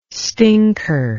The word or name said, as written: stinker